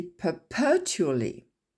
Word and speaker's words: perpetually: Perpetual, et on dit perpetually. Donc là, on ne va pas mettre l'accent tonique sur tually. Delicious, deliciously.